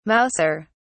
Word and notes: mauser: British English

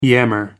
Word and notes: yammer: American English

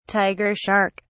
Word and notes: tiger shark: British English

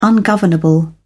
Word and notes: ungovernable: British English

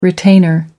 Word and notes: retainer: American English

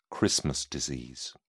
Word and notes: christmas disease: British English

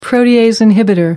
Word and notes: protease inhibitor: American English

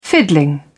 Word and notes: fiddling: British English

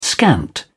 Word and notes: scant: British English

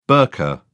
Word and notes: burqa: British English

